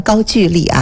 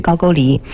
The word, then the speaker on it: 高句丽: 他去打辽东大地上的一个强国高句丽，啊，这个不要混为是高丽，啊，也不要读成高句丽啊，它的正音是高句丽。